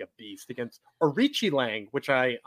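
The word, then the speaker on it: Aoriqileng: Oh, yeah, I said Aori Ki Lang or something like that. Yes. But it's Arichi Lang,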